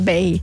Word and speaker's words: bae: scritto B-A-E. – B-A-E, okay. Però sì, si pronuncia BEI.